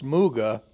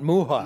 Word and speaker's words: Muga: Which, by the way, Spanish wine aficionados is pronounced Muga because he is not Spanish. – Right. – It is not Muja.